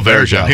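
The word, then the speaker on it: Varejão: Anderson, I always forget how to say his Varejao. – Last name. – Oh, Varejao.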